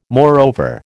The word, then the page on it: moreover: American English